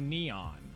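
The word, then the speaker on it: N3on: I think that you don't know because his name is Neon. – No, it's spelled N, the number 3-O-N. Entherion. – Yeah, but he goes by Neon.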